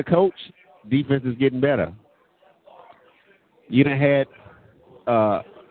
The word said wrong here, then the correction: Asiata: how do you pronounce the last name, the running back, Matatisa? – Asiata, excuse me, and Jerick McKinnon.